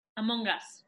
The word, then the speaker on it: Among Us: Among Us.